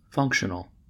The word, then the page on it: functional: American English